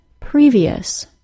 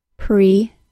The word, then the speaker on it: previous: Unless there is a T, C, or X before the IOUS suffix, the suffix is pronounced as ious with two vowel sounds. First the long E, then schwa. Going back to our initial example word, we have previous, pre-vi-us.